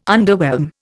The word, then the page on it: underwhelm: Indian